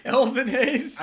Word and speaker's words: Elvin Hayes: Evan Hayes. – This man already got his name butchered. – You didn't make it. What? – Elvin Hayes.